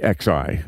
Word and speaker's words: Xi: Xi, X-I. – Of like, communist yeah. China. Yeah, it's spelled X-I, pronounced Xi, like she mm-hmm.